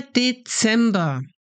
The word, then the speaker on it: Dezember: Und zum Schluss, finally, zum Schluss, der Dezember. We start with D, then in ZEM, you need to pronounce the Z like TS, ZEM, Dezem. And then we attach our ba, Dezember.